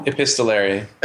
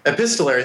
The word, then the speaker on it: epistolary: I don't know how to pronounce this word. Epistolary manner? – Epistolary. – Epistolary. Thank you.